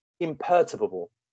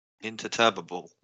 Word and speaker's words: imperturbable: The next word is imperturbable. – Sorry. – It is very imperturbable. I-M-P-E-R-T-U-R-B-A-B-L-E. Imperturbable. – Imperturbable. That's such a stupid word. Okay. – Imperturbable. – Interturbable.